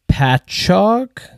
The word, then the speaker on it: Patchogue: You said you're out in Patchogue? – Perfectly said, yes.